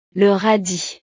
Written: le radis

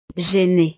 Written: gênée

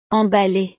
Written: emballe

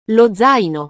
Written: lo zaino

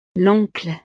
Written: l'oncle

